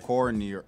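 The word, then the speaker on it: Cordinier: Cornier. – Let's see. – But yeah. But since he's French, it's Cornier. – Cornier. – That's a French lesson for y'all.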